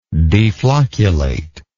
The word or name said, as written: deflocculation